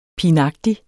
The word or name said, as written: pinagtig